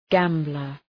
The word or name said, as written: gambler